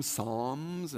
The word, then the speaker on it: Psalms: They actually pronounce the L slightly like I do. Some people just say Psalms, like S-O-M, Psalms, and it's really a, who says what?